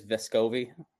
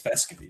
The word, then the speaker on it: Vescovi: It is Vescovi. Or Vescovi, right? – No, it's Vescovi. You just Vescovi. Said it wrong. – Yeah, Vescovi. I corrected myself.